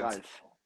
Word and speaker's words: Ralf: Ralf.